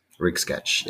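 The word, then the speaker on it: Ryxe Catch: How do you pronounce it? Rick's Rick's, Rick's. Catch. R Y X E catch, like a catch, C A T C H. Okay, because I wasn't uh, thought before I butchered some French word, I wasn't sure if it was Rix, if it was rice, so I thought I'd ask you. Rix catch, yes.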